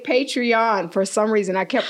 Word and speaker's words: Patreon: Also watch our Patreon episode. Did I say it right? – Yes. – Thank you. – She didn't say Patron.